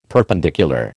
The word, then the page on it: perpendicular: American English